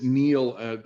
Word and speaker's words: Neal: It's Neal. I spell it N-E-A-L because my mom wanted me to spell my name for everybody for the rest of my life instead of N-E-I-L.